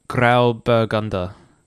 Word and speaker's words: Grauburgunder: I think the best we can get is Grauburgunder.